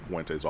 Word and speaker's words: Fuente: Fuente's offense. Oh, P.S., by the way, and I love that you make the point to say Fuente every single time because that's how he said his name is pronounced.